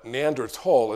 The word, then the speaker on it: Neanderthal: So Neanderthal is how it is pronounced. It's the Neander Valley, and Tall, T-H-A-L in German, means valley.